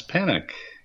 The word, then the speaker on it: Panek: My name is Thomas Panik. Not to be confused with Don't Panic. My last name is spelled P-A-N-E-K.